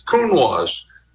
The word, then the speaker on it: Counoise: How do you pronounce it's Counoise?" Because in French, if you cover the S with a vowel, you pronounce the S.